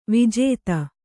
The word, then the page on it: ವಿಜಾತಿ: vijāti